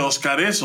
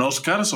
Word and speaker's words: Óscares: hoy ganan Óscares, ganan, ¿sí se dice Óscares o ganan Oscars, o cómo se dice? – Eh, Óscares.